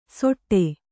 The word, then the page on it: ಸೊಟ್ಟೆ: soṭṭe